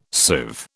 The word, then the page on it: sieve: British English